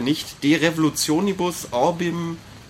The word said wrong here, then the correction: Orbium: Orbium Celestium,